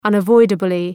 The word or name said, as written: unavoidably